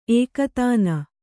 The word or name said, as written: ಏಕತಾನ